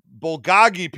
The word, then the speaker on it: bulgogi: That is one of the best tasting things outside of the Korean bulgogi pizza they make. The greatest pizza I've ever had in my entire life. – How do you pronounce that word? – Bulgogi.